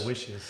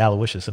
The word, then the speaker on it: Aloysius: Aloysius. – No, I don't know how he pronounced it, but he didn't say Aloysius.